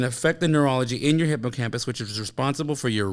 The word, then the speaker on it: hippocampus: As it compromises the neurology in the hippocampus. Am I saying that right? – Yes, you are.